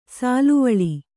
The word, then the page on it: ಸಾಲುವಳಿ: sāluvaḷi